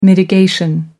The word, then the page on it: mitigation: American English